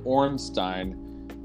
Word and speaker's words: Ornstein: So I say Ornstein because the section Stein is spelled the same way as Frankenstein. Right? It's S-T-E-I-N. Okay. So I imagine it having the same sound. – Dean would be I-E-N. – Huh? – Dean would be I-E-N.